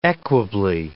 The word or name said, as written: equably